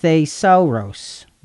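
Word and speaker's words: thesauros (θησαυρός): And it's interesting, we're going to come back to this in a little while, but the Greek word for treasure is pronounced thesauros.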